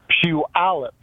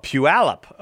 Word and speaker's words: Puyallup: Second, how do you say the name of your town? – Puyallup. It's an Indian name. – Oh, Puyallup.